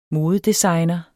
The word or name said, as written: modedesigner